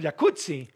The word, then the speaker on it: jacuzzi: Entonces deberíamos pronunciar el jacuzzi. – Exacto. – Como pizza. Como rampazzo, como Claudio Rampazzo.